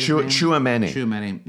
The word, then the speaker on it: Tchouaméni: That allows, was it Tchouaméni? Is that how you say it? – Tchouaméni. Yeah.